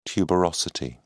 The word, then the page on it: tuberosity: British English